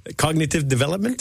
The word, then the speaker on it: Cognitive Development: Cognitive Development.